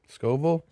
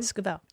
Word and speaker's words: Scovel: Did I say that right? Is it Scovel? – Scovel. Yeah. Should I put these on? – Scovel. – Oh, yeah. Better.